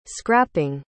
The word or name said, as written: scrapping